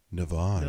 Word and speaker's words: Navage: You have to say it like this, Navage.